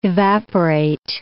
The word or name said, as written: evaporate